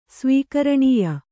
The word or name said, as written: ಸ್ವೀಕರಣೀಯ